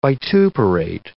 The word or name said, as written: vituperate